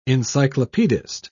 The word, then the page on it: encyclopedist: British English